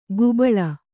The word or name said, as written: ಗುಬ್ಬಳ